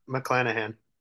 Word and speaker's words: McClanahan: McClanahan.